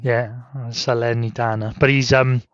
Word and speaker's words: Salernitana: So the links currently are Leicester, Southampton, Everton, Leeds, Forest, and then two Italian clubs correct me if I say this wrongly. You're the Italian expert here. Um, Saler... Salernitana. – yeah, that's it.